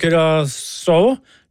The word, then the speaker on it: Curaçao: Og så har det vært en liten diskusjon her på morgenen, Eivind, om hvordan landet Curaçao, eller Curaçao, uttales. – Ja, jeg fortsatt språkforvirret. Kommer melding her fra Arne, som sier navnet Curaçao, Curaçao, uttales akkurat slik på norsk.